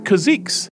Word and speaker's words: caziques: The word was spelled C-A-Z-I-Q-U-E-S, pronounced Kazix, which is a type of Oriole.